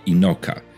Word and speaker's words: Enoch: Mam na myśli Enocha, czy jak z angielska się wymawia jego imię Inoka.